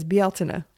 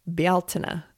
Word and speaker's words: Bealtaine: the correct pronunciation for this word, which is an Irish word, is Bealtaine. And how am I doing? Bealtaine.